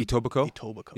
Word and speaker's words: Etobicoke: Etobicoke. Yeah, so some would pronounce it Etobicoke. – Okay. – My first pronunciation was Etobak. – Okay, all right. Etobak.